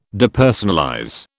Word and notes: Depersonalize: British English

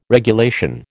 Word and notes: regulation: American English

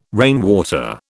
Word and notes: rainwater: British English

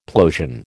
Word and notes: plosion: American English

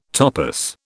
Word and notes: topos: British English

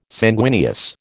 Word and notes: sanguineous: American English

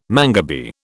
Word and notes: mangabey: British English

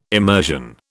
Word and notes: emersion: British English

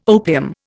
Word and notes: opium: Indian

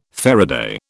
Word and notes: faraday: British English

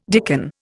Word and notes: dicken: Indian

